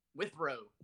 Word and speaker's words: Withrow: Withrow.